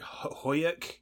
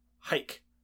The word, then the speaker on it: Huyck: I'd like to correct your pronunciation of the name. We call it Huyuk. – Huyuk. – H-U-Y-C-K. – Uh-huh. – Is hike. – Hike. – Like taking a hike.